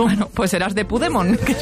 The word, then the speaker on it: Puigdemont: Para permitir que Puigdemont. – El poder que hay en Pokémon. – Puigdemont. – Hazte con todos. – Pues hazte con todos. Puigdemont. – Te diré que es difícil decirlo peor.